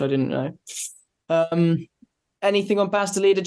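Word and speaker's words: Bas de Leede: and Chev's compatriot Baz de Leader. Is that the right pronunciation? – That's pretty good.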